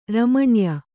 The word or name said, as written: ರಮಣ್ಯ